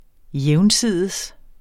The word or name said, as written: jævnsides